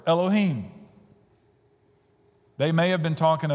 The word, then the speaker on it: Elohim: The Hebrew word here is Elohim, just like it's spelled, H-I-M at the end, Elohim.